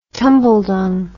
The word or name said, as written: tumbledung